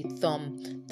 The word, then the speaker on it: thumb: The biggest one is called a thumb. Not a thumb. Alright, it's a thumb. The B is silent as well.